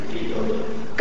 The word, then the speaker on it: Kivioja: Have I pronounced it correctly? – Very close. – Kivioja? – Kivioja. – Good. – Pronounce the J.